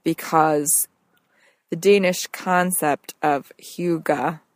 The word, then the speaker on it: hygge: It's spelled H-Y-G-G-E, and it's pronounced hygge.